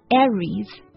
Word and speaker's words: Aries: Aries。那它的这个发音呢稍微奇怪一点，就是这个 A 是吧，它发的是 red 那个小 E 的那个音，而不是梅花 I，大家要注意了。